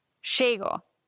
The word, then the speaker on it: llego: Doppel-L wird wie ein J ausgesprochen, auf jeden Fall im Castellano. Die Südamerikaner machen es manchmal ein bisschen anders. Zum Beispiel die Argentinier sagen Chego. Da wäre Doppel-L ein Sch.